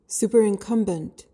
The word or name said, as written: superincumbent